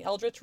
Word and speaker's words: Eldritch: And then now we have this deck called the Eldritch Overload Tarot. Am I pronouncing Eldritch right? – Yes. Like Eldritch Horror.